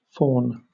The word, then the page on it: fawn: British English